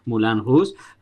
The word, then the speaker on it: Moulin Rouge: Moulin Rouge, es con Watchmen, no sé María si lo he dicho bien. – Sí, lo has dicho bien.